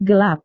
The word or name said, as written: gelap